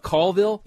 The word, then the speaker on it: Colville: But I want to have you one do about Colville, you know, because Colville is always mispronounced as Colville. – Mm-hmm. – But there's that Blondie song, Call Me.